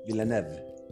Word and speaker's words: Villeneuve: Esta está dirigida por Denis Villeneuve, no sé si se dice así, Denis Villeneuve es francés. – Villeneuve.